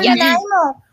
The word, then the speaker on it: Nanaimo: Now, what we don't have in the U.S., as far as I know, is Nanaimo bars. Am I saying that right? – Yeah, Nanaimo.